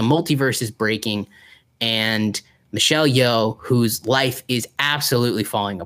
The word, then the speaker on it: Yeoh: It is the new Michelle Yeoh movie.